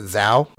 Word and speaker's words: Zhao: How do you say that? Shao? Show? – How is it spelled? – Z-H-A-O. Oh, he's Zhao. A Chinese film. So Zhao or Shao? – Zhao.